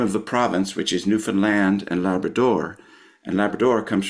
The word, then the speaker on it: Newfoundland: One Newfoundlander in the Broadway company, she taught us that in school, the kids are instructed to say, understand Newfoundland, which both have third syllable stress, and they both have that ah sound for the land and stand.